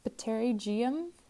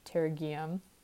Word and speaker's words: pterygium: Pteri is like, you know, the pterygoid, so it's probably pterygium. That might be how you pronounce it. But it starts with a P, so it's P-T-E-R-Y, like the pterygoids.